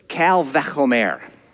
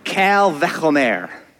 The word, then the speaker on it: Kal Vechomer: First, you have to repeat after me a Hebrew phrase, Kal Vechomer. Kal Vechomer. You got to get a little phlegm going to say it properly, but that's what the phrase is.